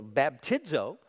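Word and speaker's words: baptizo (βαπτίζω): It's pronounced a little differently. It's called baptizo, but it's not a translation, it's a transliteration.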